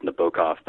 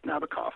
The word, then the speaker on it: Nabokov: I know his name is pronounced Nabokov, but I don't care. I'm American, and it's Nabokov because that's how Sting says it in that stupid song.